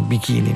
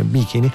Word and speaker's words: Bikini: Il 21 maggio del 1956, nell'Oceano Pacifico, presso l'atollo di Bikini, poi tutti pronunciano Bikini, ma dovrebbe essere Bikini, viene fatta esplodere The Short Red Wing Cherokee.